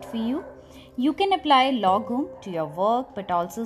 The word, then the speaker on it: lagom: So now, our next word is lagom. It's L-A-G-O-M. And it's pronounced as lagom.